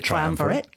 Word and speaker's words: triumvirate: We were talking, you used the word, which I've never heard in my life before, so casually, triumvirate. – Yes. – Is that how you say it? – Yeah, triumvirate.